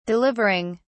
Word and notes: delivering: British English

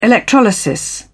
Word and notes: electrolysis: British English

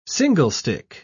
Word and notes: singlestick: British English

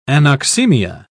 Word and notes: anoxemia: British English

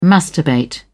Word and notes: masturbate: British English

